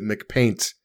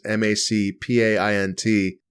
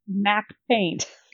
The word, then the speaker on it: MacPaint: You may have guessed that I pronounce it McPaint. This is the paint program that came with early Macintoshes, MacPaint. – MacPaint. – Yeah. Uh-huh. I've always pronounced it McPaint.